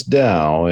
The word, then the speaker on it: Tao: Oh, sir, you pronounce Tao as Tao in Chinese.